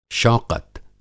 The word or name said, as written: शौक़त